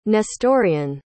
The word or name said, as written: Nestorian